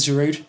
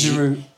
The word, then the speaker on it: Giroud: I don't think you can. I think you have to not pronounce the D. – Giroud.